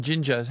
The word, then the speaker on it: Jinja: give us a ring from Uganda, from Jinja. How do you spell it? – I will. – It's not not in the stuff you eat, is it? – No, it's J-I-N-J-A. J-I-N-J-A.